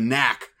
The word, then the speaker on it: Knack: Knack confused. – Yeah, well, the key is in the knack, the K is silent.